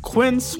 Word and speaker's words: Quince: Today Explained support today comes from Quince, which rhymes with since, but is spelt with a Q-U.